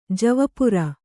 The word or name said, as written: ಜವಪುರಿ